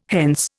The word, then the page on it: hence: Indian